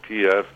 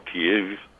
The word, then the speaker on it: Kyiv: And though, you know, the Ukrainians themselves, under their president Volodymyr Zelensky in their capital Kiev, or Kyiv, as we should more properly pronounce it, they also get to have their say, although they can't help feeling that they're a bit like pawns in this game.